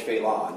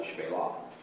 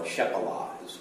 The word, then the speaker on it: Shephelah: It's called the Shephelah, or Shephelah, if you were to pronounce it literally in English, it's Shephelah.